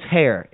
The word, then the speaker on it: Hair: My last name is Hair, H-A-I-R. – Dude, you are pathetic. Seriously, spell it. – H-A-I-R, Hair.